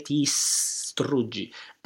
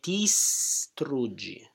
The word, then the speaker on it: distruggi: That is very important because we have STR, mm-hmm. three consonants together. Mm-hmm. So we have to pronounce precisely three consonants. Str. It's very important to have the S in the upbeat. – That it really starts early. So we're cheating the T. – Yeah, exactly. Tistr. Memorie distruggi. And also I suggest to add the S to the syllable before. Che memorie distruggi.